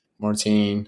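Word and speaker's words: Maurten: Again, I feel like a lot of people use those Martin gels. If you pronounce it Martine, everyone knows what we're talking about.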